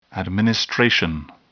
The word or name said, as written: administration